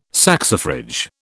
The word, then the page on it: saxifrage: British English